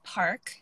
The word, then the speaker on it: Parque: It's spelled P-A-R-Q-U-E, but pronounced Park.